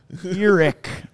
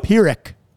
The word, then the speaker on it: Pyrrhic: Definition of a Pyrrhic victory. – Hey, you pronounced that correctly. – Pyrrhic.